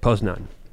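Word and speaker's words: Poznań: Yeah, but the issue is, if Poznan, is that how you say it? – Poznan.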